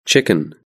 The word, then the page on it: chicken: British English